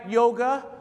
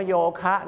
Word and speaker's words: yoga: ครับดังนั้นครับวิธีการจำนะครับก็คือ vegetarians ก็คือคนกินเจนะครับ vegetarians like to eat vegetables ครับผมนั่นแหละครับต้องขอโทษจริงๆครับผมต่อไปแล้วครับโยคะครับฝรั่งเขาเรียกว่าโยเกะนั่นเองครับดังนั้นครับถ้าฝรั่งก็ถามคุณว่า Do you like yoga คุณบอก Yes I like strawberry เขาไม่ได้ถามว่าชอบโยเกิร์ตหรือเปล่านะครับเขาถามว่าโยเกะดังนั้นครับเราออกเสียงว่าโยคะนะครับแต่ฝรั่งออกเสียงว่าโยเกะ